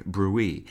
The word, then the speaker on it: bruit: The English noun brute is now considered archaic, apart from a medical sense that is pronounced like the French word bruit, and refers to one of the abnormal sounds heard on auscultation.